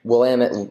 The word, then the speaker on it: Willamette: Willamette River. – Yeah, it rhymes with damn it.